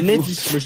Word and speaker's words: Na'vi: Les Na'vi.